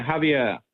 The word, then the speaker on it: Javier: Is that how you pronounce his name? – Yeah, Javier. Yeah.